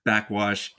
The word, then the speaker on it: Backxwash: So it's spelled one word, BACKXWASH. Backwash is coming out on May 7th with an album with, I think, an today.